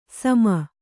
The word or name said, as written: ಸುಮ